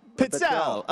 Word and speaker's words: pizzelle: Well, how do you say it? Uh, a- Pizzelle. A pizzelle. What is this, Michael? A pizzelle. It's Italian.